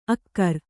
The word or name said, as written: ಅಕ್ಕರ